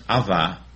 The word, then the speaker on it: Abba: That's pronounced Ava, but it's spelled A-B-A-A-B-B-A.